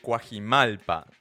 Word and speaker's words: Coajimalpa: No, Che, la banda es, eh, son originaria, la banda es originaria, perdón, de Coajimalpa. ¿Está bien pronunciado así? Sí. Sí.